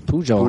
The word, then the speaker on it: Pujol: I think it's Pujol. – Pujol. – Pujol? Really? – Yeah. – They pronounce the J? – Yeah, because the baseball player is Pujol.